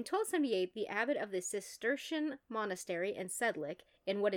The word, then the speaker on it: Cistercian: Like Cistercian? Like a cistern? – Like a cistern.